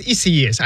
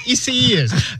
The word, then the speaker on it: Isaias: It's Isaias or Isaias. No, it's Isaias. I just heard it. It's Isaias. But do you know that 14 hurricanes or names of hurricanes that start with the letter I have been retired?